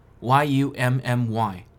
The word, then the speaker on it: yummy: 在昨天的节目中，我们学到了 yummy y u m m y 这个单词的正确发音是 yummy，而不是昨天我说的 yummy。感谢听众 Kions